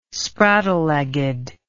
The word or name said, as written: spraddle legged